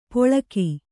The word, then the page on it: ಪೊಳಕಿ: poḷaki